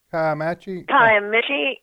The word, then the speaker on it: Kiamichi: Kiamichi? – Kiamichi.